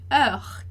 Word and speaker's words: heure: Bom, como nem o H nem o ES são pronunciados, e aí o que sobra de pronúncia é o heure. Heure, ali do meio. Então, o E, U, R. Heure, que é o que a gente efetivamente vai pronunciar dentro dessa palavra.